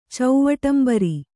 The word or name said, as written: ಚೌವಟಂಬರಿ